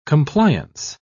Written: compliance

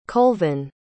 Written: colvin

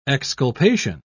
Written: exculpation

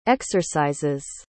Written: exercises

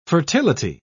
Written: fertility

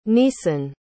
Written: neason

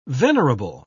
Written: venerable

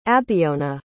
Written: abiona

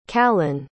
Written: khalon

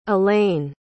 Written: alane

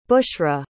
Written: bushra

